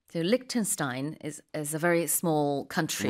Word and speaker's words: Liechtenstein: In English, we say Liechtenstein. – Liechtenstein. – But okay. – I think the way you're saying it is the closer to the German pronunciation.